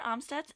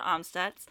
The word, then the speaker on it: Amstutz: I'm Sandra Omstutz, and you can find me on all my social media platforms at Sandra Omstutz.